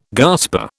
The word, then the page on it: gasper: British English